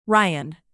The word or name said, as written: ryan